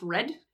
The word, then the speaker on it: rod: One is rod, R-O-D, it's spelled R-O-D, but it's pronounced red.